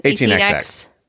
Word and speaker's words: 18XX: I love to play the Gallerist or Lisboa or any, how do we say it, 1800s? – 18XX. Literally. 18XX.